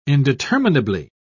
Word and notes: indeterminably: British English

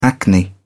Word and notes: acne: British English

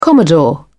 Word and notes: commodore: British English

